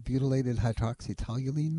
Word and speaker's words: butylated hydroxytoluene: Is that something called butylated hydroxytoluene, ma'am? – Yes, you said it right.